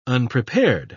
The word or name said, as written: unprepared